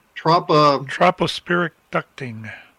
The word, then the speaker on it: tropospheric ducting: Tropospheric ducting.